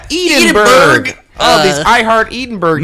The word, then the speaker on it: Edinburgh: Way to pronounce it really correctly for Is that right? – Is it Edinburgh? – Edinburgh. – Fuck. Sorry.